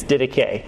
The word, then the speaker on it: Didache: You might have wanted to pronounce it Didache, but it's not Didache. It's Didache. It's from the Greek term teaching, okay?